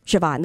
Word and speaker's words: Siobhan: Like, Siobhan is not at all how you think it's spelled. Siobhan, the name Siobhan.